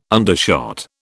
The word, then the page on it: undershot: British English